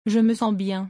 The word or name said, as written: Je me sens bien.